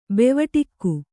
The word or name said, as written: ಬೆವಟಿಕ್ಕು